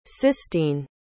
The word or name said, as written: sistine